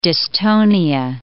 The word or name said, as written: dystonia